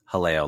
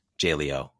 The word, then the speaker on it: Jaleo: but it is pronounced Haleo, Leslie, not Jaleo or anything like that.